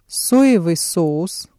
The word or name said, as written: соевый соус